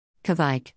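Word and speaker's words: kveik: Kvike, pronounced Kvike or Kvike, is commonly and wrongly referenced as being a style of beer, but rather the name refers to the yeast itself.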